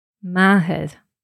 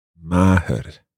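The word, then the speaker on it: màthair: Mahar. – Mahar. – Mahar.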